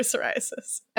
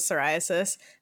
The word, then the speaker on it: psoriasis: psoriasis and trying to tell them that the P is silent?